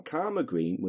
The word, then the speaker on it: Coldmore Green: Now, Karma Green was how it was pronounced by the locals, and it would have only been pronounced this way by someone who was versed in the local dialect.